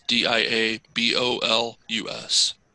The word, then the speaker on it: Diabolus: Kol Diabolus, D I A B O L U S.